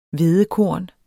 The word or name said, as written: hvedekorn